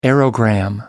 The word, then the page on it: aerogramme: American English